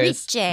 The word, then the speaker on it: Reich: And it's hosted by a guy called Sam Reich? Reich? Reich? One of those. – Reich. – It's spelled R-E-I-C-H.